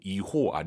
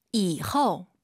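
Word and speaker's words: 以后: 이호 아니에요. 오유 발음은 어우예요. – 이후.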